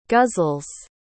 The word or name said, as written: guzzles